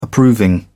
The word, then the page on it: approving: British English